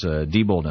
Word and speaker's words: Diebold: or some say Diebold, I think Diebold is the correct pronunciation,